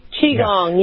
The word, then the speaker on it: Qigong: I'm pronouncing it correctly? Qigong? – Yes, Qigong, yeah.